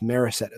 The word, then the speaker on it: Morissette: Sal Frelick, the center fielder, I really, really like him, and uh, Cody Morissette, or I've heard it pronounced Morissette as well, um, who had a slow start and then was three for four with two bombs the other day.